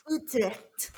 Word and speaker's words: Utrecht: Utrecht.